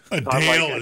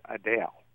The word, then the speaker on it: Adele: She did she was doing a Q&A with fans, and one of the fans, you know, addressed her as Adele with the emphasis on the second syllable.